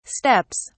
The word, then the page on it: steps: British English